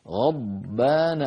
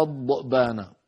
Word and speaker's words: غضبان: والضاد علينا أن ننطقها كما لفظتها الآن غضبا غضبا ولا نقول لا نقول غض بعض إخواننا بيقولوا غض بانا ضؤ هذا الصوت ضؤ هذا صوت زائد لا معنى له الصواب أن نقول